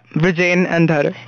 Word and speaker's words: Vrujen Andhare: So wait, did I get your name correctly? – Yeah, Vrujen, Andhare.